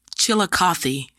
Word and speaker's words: Chillicothe: Well, so we're going to talk about your haunted hometown of in Ohio, and it's Chillicothe. Did I say that right? – Correct. – Chillicothe. – Yes, you did.